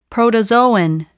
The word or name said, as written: protozoan